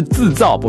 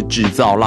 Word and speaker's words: 自造: 哎，我没有念错哈，我没有把它念成平翘舌部分啊，是自造，不是制造浪漫。自造自己的自，造是躁动的躁。